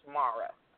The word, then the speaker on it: Mara: And also, my name is pronounced Mara.